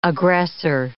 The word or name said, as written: aggressor